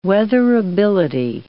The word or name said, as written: weatherability